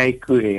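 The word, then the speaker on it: IQAir: Sì, eh, la pronuncia esatta, sai, gli svizzeri sono, sono esagerati, è IQL.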